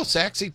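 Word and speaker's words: Sachse: You didn't really know how to pronounce it, Saxey. – Yeah, you know? – Yeah. I had to look up the pronunciation on the word. – No, you didn't. And I looked it up, and I'm like, oh, Saxey, Texas.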